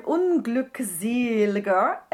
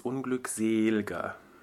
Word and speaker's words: unglückseliger: So we had the word unglückseliger, and we have there the stress syllable is the seel, and it's also a long closed E, right? – Yeah, it's unglückseliger, because it's a poem.